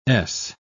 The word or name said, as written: ess